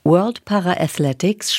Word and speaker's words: World Para-Athletics: World Para-Athletics spricht man so aus.